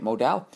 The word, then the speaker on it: Modal: Finally learned how to say it, Modal.